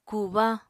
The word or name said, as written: kubañ